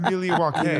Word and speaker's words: Milwaukee: And he's like, actually pronounced Milwaukee.